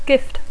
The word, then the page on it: gift: English